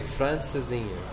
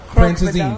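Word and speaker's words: Francesinha: How do you say it? – Francesine. – Francesine. – Yeah, Francesine.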